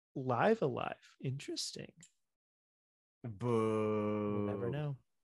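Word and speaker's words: Live A Live: But see, uh, live, live, live alive, live alive, live alive. Again, I'm pretty sure cool. it's live alive. Oh, yeah, I think you're right.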